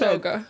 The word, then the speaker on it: Jauga: I think this might be Hauga. I'm not sure. – Oh, it is Hauga. – It's spelled J-A-U-G-A. – I spelled it J-A-G-U-A.